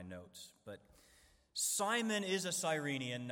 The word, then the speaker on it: Cyrenian: And Cyrenian actually has a homophone in modern English, a word that sounds exactly the same as Cyrenian. Cyrenian, if you spell it S-I-R instead of C-Y-R, it refers to an animal in the genus Cyrenia, which includes dugongs, manatees, and sea cows.